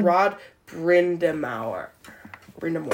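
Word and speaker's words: Rod Brind'Amour: Rod Brindamour.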